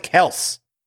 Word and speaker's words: Kelce: We're supposed to pronounce it Kelce. Did you know that? – I did not know that.